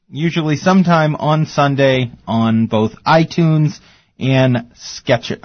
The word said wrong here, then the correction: Stitcher: I always say it wrong. Stitcher? – Stitcher.